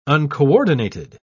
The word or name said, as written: uncoordinated